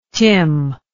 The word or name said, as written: tim